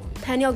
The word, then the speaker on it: pineal: It's like pineal gland. I don't know how to pronounce it. – Pineal gland.